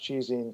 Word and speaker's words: ChiZine: It is technically pronounced Chizine because it's short for chiaroscuro.